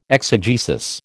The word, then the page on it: exegesis: American English